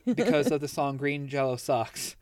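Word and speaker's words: Green Jellÿ: So that's why it's spelt green jelly, but you got the little dots that make it uh, pronounced green jello.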